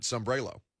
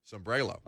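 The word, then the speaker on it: Sambrailo: I pronounce it Sambrello. – How does Ty pronounce it? – Sambrello. – Okay, then we're good.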